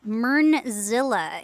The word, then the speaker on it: Myrnzilla: Yeah, you can find me on Instagram and Twitter as Myrnzilla. It is spelt MYRNZILLA. So like Godzilla with Myrn in the front.